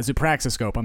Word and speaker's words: zoopraxiscope: So it's his zoopraxioscope. – Zoopraxioscope? – Zoopraxoscope? Did I don't know. – add a syllable? Yeah, zoopraxoscope, I'm sorry.